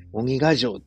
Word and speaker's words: 鬼ヶ城: それからこれなんて読むやろ? 鬼ヶ城? 鬼ヶ城って読むの? わかんないですけど、水野さんミドルの部で優勝してましたね。おめでとうございます。すごい。最後ゴール手前で、ウィニングランで、応援に来てた奥さんと、なんていうの? 何? ダッシュで競争してましたけどね。鬼ヶ城って読むんや。